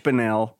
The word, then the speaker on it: Bunnell: My name is Rich Bunnell, pronounced with an umpty, and I'm here with...